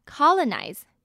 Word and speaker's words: colonize: 再我们来看 Colonize 呢指的是开拓殖民地，它是动词，这边的第一个 O 呢是发 R 的音，第二个 O 只是发短音耳的音，所以会念成 color， N-I-Z-E 发 nize 的音， colonize。